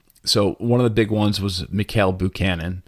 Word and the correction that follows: Bakunin: Or I think it's pronounced Bakunin.